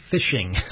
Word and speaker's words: phishing: And finally, on the retail business, I guess, I got this from my bank, a whole little pamphlet on what is phishing, spelled P-H-I-S-H-I-N-G. It's a really weird times we're living in. Phishing, pronounced like fishing with the F, isn't it?